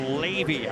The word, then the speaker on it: Loewe: Her name is spelled L-O-E-W-E. Looks like low, but it's pronounced Levy out of Ballard High.